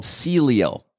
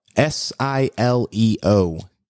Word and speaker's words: Sileo: It's called Sileo. S I L E O.